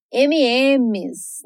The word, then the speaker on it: M&M's: E agora um doce meio parecido com os Skittles, os MMs, né? – Que são pronunciados M&Ms. M N M's. M&M's. Sim, parece o rapper Eminem, bem parecido mesmo.